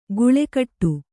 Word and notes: ಗುಳೆಕಟ್ಟು: guḷe kaṭṭu

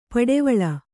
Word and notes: ಪಡೆವಳ: kannada

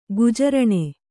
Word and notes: ಗುಜರಾನು: gujarānu

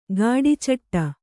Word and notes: ಘಟಚೇಟಿ: ghaṭa cēṭi

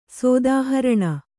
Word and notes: ಸೋದಾಹರಣ: kannada

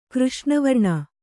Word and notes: ಕೃಷ್ಣವರ್ಣೆ: křṣṇa varṇe

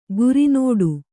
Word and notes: ಗುರಿನೋಡು: guri nōḍu; kannada

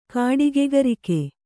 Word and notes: ಕಾಡಿಗ್ಗರಗೆ: Kannada

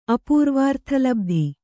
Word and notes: ಅಪೂರ್ವಾರ್ಥಲಬ್ಧಿ: Kannada

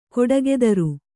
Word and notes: ಖಡುಗಧಾರೆ: khaḍuga dhare

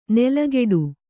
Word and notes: ನೆಲಗೆಡು: nelageḍu

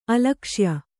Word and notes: ಅಲಕ್ಷ್ಯ: alakṣya

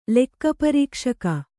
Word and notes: ಲೆಕ್ಕಪರೀಕ್ಷಕ: Kannada

